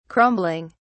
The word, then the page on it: crumbling: British English